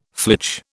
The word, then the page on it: flitch: British English